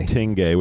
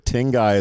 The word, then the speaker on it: Tengai: It's pronounced Tengai. – Tengai. – Not Tengai, which we will probably mess up in the interview. But Tengai is officially how you pronounce it.